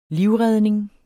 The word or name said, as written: livredning